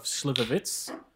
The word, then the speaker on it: Slivovitz: So after I do my shot of Slivovitz, did I pronounce it right? – Yeah.